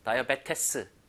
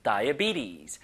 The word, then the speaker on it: diabetes: 다이아베테스 이게 아니라 다이비티스, 다이비티스. 너무나 이상하게 읽히는 단어입니다. 실제 표기하고 너무 달라요, 읽는 게. 그죠? Diabetes.